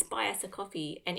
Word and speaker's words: Ko-fi: I think it's called coffee. I think it looks like it's spelled Ko-fi, but I think it's supposed to pronounce it coffee.